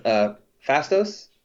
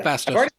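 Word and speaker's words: Phastos: I wanted more time with Fastos. Is that his name? Did I get his name right? – Yeah. – The technology one. – Yeah, Fastos. – Yeah, I loved his character.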